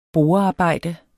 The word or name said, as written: borearbejde